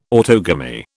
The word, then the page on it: autogamy: British English